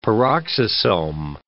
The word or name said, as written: peroxisome